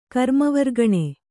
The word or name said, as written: ಕರ್ಮವರ್ಗಣೆ